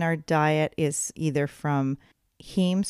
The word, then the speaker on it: heme: And that's spelled H-E-M-E. – H-E-M-E. That's right. – Heme.